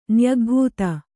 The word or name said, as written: ನ್ಯಗ್ಭೂತ